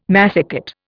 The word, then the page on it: massicot: Indian